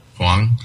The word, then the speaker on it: Hwang: I butcher this one. Jung Min Hwang? – Hwang. Yeah. – Wrong pronunciation of the A there.